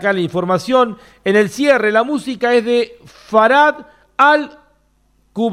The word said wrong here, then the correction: Fahad al-Kubaisi: Fahad al-Kubaisi, así se pronuncia bien.